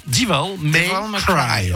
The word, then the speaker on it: Devil May Cry: Devil, on peut prononcer correctement. Devil May Cry.